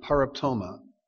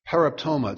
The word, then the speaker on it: paraptoma: The first one for trespasses is translated from the Greek word paraptoma. Paraptoma, it's spelled P-A-R-A-P-T-O-M-A.